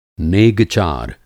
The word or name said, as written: नेग-चार